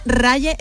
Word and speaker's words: Raye: Bueno, se escribe Raye Ray.